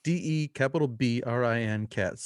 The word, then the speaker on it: DeBrincat: His name is spelled DeBrincat.